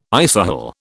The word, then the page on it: isohel: British English